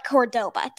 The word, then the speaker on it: Cordoba: Okay, but Cordoba. Did I pronounce that right? – Sounds right, yeah.